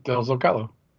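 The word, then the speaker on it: Del Zócalo: Rancho de, how do you say it? – Del Zocalo. – Del Zocalo. Okay.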